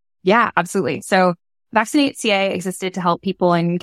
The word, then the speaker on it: VaccinateCA: And it's called Vaccinate California, I think is how you pronounce it. – So Vaccinate CA. – Vaccinate CA. Okay.